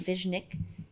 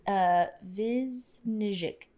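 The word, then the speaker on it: Višnjić: I do not know how to say his last name. Viznik. Viznik. It's Viznijic, but he is from ER.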